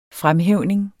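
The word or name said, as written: fremhævning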